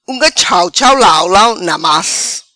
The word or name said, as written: Unga chau chau lau lau namas